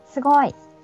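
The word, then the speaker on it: すごい: First of all, do I pronounce it correctly? No, I don't. Say it once, please. – Sugoi.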